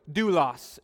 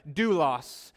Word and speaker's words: doulos: Doulos is how you pronounce that. Doulos.